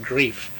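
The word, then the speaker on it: griffe: We often, to suggest a person with a certain amount of black blood, but not much, so use the local French word, grief. He's a grief. – Mm-hmm. – Just pronounce as if it were G-R-I-E-F.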